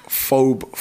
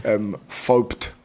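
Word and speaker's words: FOEBT: My alias is Phobe Fully, but the T's silent. But loads of people pronounce it wrong. They pronounce it Phobed. Phobed. But it's F-O-E-B-T. It's an acronym.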